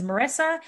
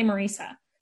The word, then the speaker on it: Marisa: Because people always say that my name is Marissa and it's really Marisa.